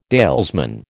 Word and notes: dalesman: American English

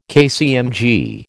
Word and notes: KCMG: American English

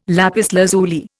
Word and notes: lapis lazuli: Indian

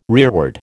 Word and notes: rearward: American English

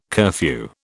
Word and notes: curfew: British English

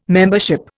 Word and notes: membership: Indian

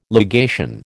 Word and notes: legation: American English

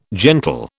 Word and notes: gentle: British English

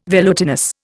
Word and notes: velutinous: Indian

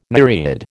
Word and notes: nereid: American English

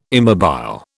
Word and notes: immobile: British English

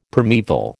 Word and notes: primaeval: American English

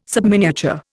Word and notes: subminiature: Indian

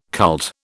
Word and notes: cult: British English

